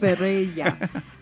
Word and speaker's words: Ferreira: And then Louis Ferreira. Am I saying that correctly, Carlos? – Sounds right. – Ferreira.